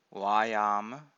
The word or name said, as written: vayama